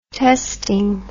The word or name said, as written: testing